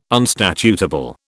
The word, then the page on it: unstatutable: British English